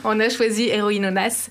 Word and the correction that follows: Héroïnas: Très bien. – Je crois que je le prononce Héroïnas. – Pas très... Ah, voilà. De votre prochain album.